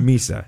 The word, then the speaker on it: Misa: And this is, do I have his name right? Is it Michael Misa? – Misa, yep. – That's how you pronounce it.